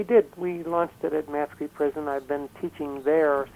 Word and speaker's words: Matsqui: You launched your book in a prison in the Matsqui, is that correct pronunciation? – Matsqui, yes.